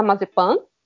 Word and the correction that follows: Mazepin: Mazepin, eu não sei como é que as pessoas estão pronunciando o nome dele, que é a, babaca.